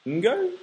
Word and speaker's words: Ngo: I said Ng, and I said, is it like fucking? And he said, yeah.